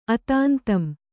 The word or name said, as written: ಅತಾಂತಂ